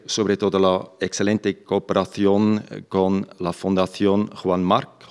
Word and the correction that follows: Juan March: Juan March.